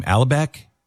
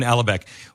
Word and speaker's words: Alibek: Alibek, is that? – Yeah, exactly. Yep. – Yeah. Okay. Ken Alibek.